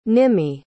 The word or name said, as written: nimmi